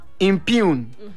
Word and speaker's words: impugn: It is spelled B-O-M-A-O-M-O. – Impugn. Where do you come from? – Yeah. And then, I-M-P-U-G-N. Impugn. G-N is silent. You don't pronounce it.